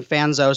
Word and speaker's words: Fanzo: So F-A-N-Z is my last name is being Fanzo.